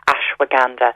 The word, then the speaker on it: ashwagandha: ashwagandha.